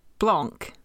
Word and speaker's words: Blanc: So, like Blanc. Yes. Well, except with the pronunciation still the same as blank.